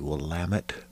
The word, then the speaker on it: Willamette: I do live in the magical place called the Willamette Valley. If you're not aware of how to pronounce it, that's how you pronounce it.